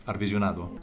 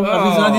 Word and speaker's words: Arvisionadu: Did I pronounce that right? Arvisionadu. – Perfect. – Really? – Your Arvisionadu is perfect.